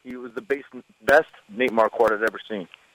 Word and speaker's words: Marquardt: Am I saying that right? Yeah, Nate Marquardt.